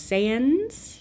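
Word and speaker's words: Sáenz: Um, Benjamin Alire Reyes. – Yeah, that's pretty close. Sands.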